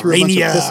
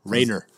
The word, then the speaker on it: Rainer: I said Rainer the first time. – No, you went like Rainier. – That was the last time. – Oh, Rainer.